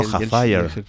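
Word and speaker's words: Hfuhruhurr: El doctor Hafayer, que se escribe así, sí. Hufvudrr. Y la gente, pues, cuando lo lee, claro, dice Flurrier. Y dice nombres muy raros. – Dice, no, Hafayer.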